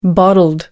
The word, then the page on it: bottled: American English